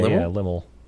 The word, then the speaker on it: Lymle: Limmel? – Is that how it's pronounced? Limmel? – L-Y-M-L-E? – Yeah, Limmel.